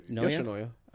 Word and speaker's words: Yoshinoya: What'd you do at, how do you pronounce it? Yoshinoya? – Yoshinoya. – Okay.